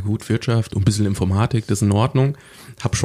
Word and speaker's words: Informatik: Und in der ersten Vorlesung sagt der Professor, naja, im Deutschen ist ja die Betonung aufs Zweite, Informatik.